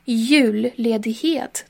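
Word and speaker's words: juledighet: ударение, здесь два ударения на самом деле. Первое падает на слово юль, а второе падает на конец э, второго слова ледхет. Юль-ледихет.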